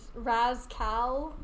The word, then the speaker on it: Raz Kal: I am currently DMing, and my last character was Raz Kal, and then you would pronounce it like Rascal.